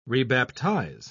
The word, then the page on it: rebaptize: British English